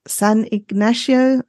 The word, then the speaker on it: San Ignacio: And the most fabulous experience of all was spending time in San Ignacio, I think it's called, Lagoon.